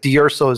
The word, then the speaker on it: D'Urso: Which actually, technically, I do not pronounce the way it is pronounced in Sicily. But that's a different story. I've Americanized it. D'Urso is good enough for me.